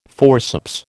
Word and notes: forceps: American English

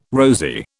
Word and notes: rosy: British English

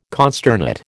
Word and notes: consternate: American English

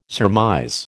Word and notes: surmise: American English

